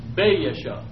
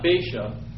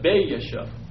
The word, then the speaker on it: Baasha: You got two A's there. And you kind of break them up when you pronounce them. Baasha. Not Baasha, but Baasha.